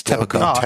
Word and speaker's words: Tapoco: How do you pronounce this historic? Tapoco? – I took it as Tapoco. – Tapoco is the way I said it. – Well, it's T-A-P. – Yeah, it's Tapoco. Tapoco.